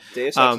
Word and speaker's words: Deus Ex: I never know how to pronounce that. – Deus Ex is correct.